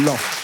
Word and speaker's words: Laon: Ça s'écrit L-A-O-N. Mais on dit bien Laon.